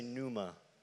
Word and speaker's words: pneuma: We get this Greek word, pneuma. Everybody say pneuma. The P is silent.